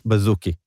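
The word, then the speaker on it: Baszucki: דייב בזוקי. בזוקי, ככה מבטאים את זה, תודה רבה.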